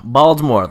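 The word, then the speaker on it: Baltimore: Baldmore, the correct way to pronounce it, probably. I went to Maryland once, I heard someone say it, and that's the doctrine. That's how. That's the correct way to do it.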